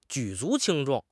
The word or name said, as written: 举足轻重